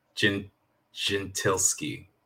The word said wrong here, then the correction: Gentileschi: Well, I mean, I'm not Italian, but the correct sort of a pronunciation, Artemisia Gentileschi.